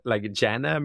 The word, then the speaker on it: jhana: I do what's called the jhana meditation, which is very wonderful. – Spell it for me, please. – J-H-A-N-A.